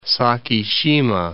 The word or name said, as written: Sakishima Islands